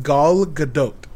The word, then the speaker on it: Gal Gadot: Her name is pronounced Gal Gadot. – Gal Gadot. – Hmm. Yep. – Okay. – And there's no French. I was wrong.